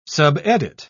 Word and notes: subedit: British English

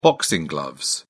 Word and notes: boxing gloves: British English

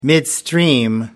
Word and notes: midstream: British English

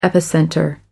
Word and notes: epicenter: British English

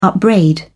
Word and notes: upbraid: British English

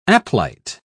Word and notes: aplite: British English

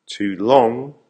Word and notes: too long: American English

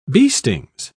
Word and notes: beestings: British English

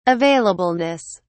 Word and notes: availableness: British English

